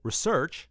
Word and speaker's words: research: For this week's suggested dive into the land of research, bonus points for emphasis on the second syllable, research, check out Rayleigh scattering for the answer.